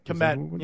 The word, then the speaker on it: Kmet: Kmet.